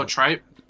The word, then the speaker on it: tripe: Trite. Is it trite? Like it starts... – Oh, tripe? – Yeah, trite.